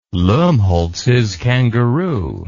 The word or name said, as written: lumholtz's kangaroo